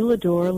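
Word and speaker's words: Ladeur: The name is Judy Ladur. Ladur is spelled L-A-D as in David, E-U-R.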